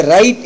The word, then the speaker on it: write: இந்த W வந்து இங்கே வந்து சைலண்டாக ஆகுவதால் இதை ரைட் என்பது தான் உச்சரிக்க வேண்டும்.